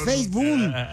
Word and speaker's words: Facebook: Ya sé que es un show, pero pronuncia bien Facebook. – Renuncia. – No es Facebook. Y también Instagram, no es Instagram, es Instagram.